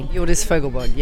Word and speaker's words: Hjordis Fogelberg: Hjordis Fogelberg, yes.